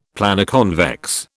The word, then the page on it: Planoconvex: British English